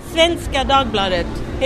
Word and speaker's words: Svenska Dagbladet: Svenska Dagbladet.